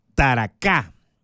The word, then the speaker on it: Taracá: La canción, pues es el primer adelanto de su próximo álbum que se llamará Taracá. Sí, porque tiene el acento en la última A. O sea, es aguda, grave, esdrújula.